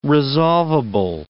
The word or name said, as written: resolvable